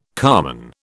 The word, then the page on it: carman: British English